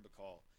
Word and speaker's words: Bacall: And Gemma Grutner Bacall, I believe it's pronounced, like Lauren Bacall, the junior college shooting guard, was top 15 in the nation in scoring.